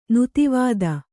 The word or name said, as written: ನೆತ್ತಿವತ್ತು